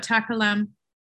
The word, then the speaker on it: Takalam: I'm so interested to hear about Takalam. Am I saying it right? – Correct. Okay.